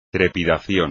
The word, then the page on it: trepidación: espagnol